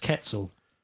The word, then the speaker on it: Quetzal: Well, it's spelled the Quantal Quetzal, but according to Wikipedia. And some other people I saw shouting at a video on YouTube, it's pronounced Quetzal.